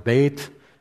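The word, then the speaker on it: Beit (בית): At the beginning, it says Aleph, and then at the next, before verse 9, it'll say, it looks like Beth, but it's actually pronounced Beit, and then in above verse 17, it's Gimel, and then Daleth, and then He, Vav.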